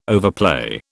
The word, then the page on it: overplay: British English